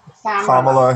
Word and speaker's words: Kamala: Which brings us back to, Kamala, about how You say it like Pamela, but with a K. It's Kamala Harris.